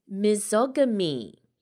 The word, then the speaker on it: misogamy: Okay, it's misogamy. M-I-S-O-G-A-M-Y. Misogamy. Do you know? – Sounds like you're mispronouncing misogyny. – It does, doesn't it? But I'm not. Mm-hmm. Yes, I'm not mispronouncing misogyny.